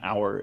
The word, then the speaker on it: hour: It is not just a name that they threw in there because it was kind of alliterative, even though the H in hour is is silent in, in the way we say it.